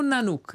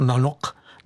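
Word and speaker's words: Nanook: Naspívala ji kapela, já bych to přečetla jako Nanuk, jo? Mm-hmm. Ale prý se to tak v Grónsku úplně nečte. – Čte se to Nanuk.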